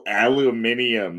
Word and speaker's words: aluminium: That's not how we say it. – Mm-hmm. That is how you say it. I've heard you say it multiple times like no. – That. – It's aluminum.